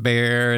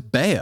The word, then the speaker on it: Bayer: I thought it was Bayer. – Bayer. – Well, Bayer. – Bayer. Bayer. I think Europeans pronounce it which is the actual correct way.